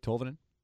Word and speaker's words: Tolvanen: But they drafted this kid, Tolvanen. Did I say it right? Tolvanen? Ellie? – Sure. – I think that's right.